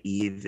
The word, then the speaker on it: Yves: So the opera the opera was called Adam and Eve, but Eve is spelled Y-V-E-S, like the French Eve, which is a male name.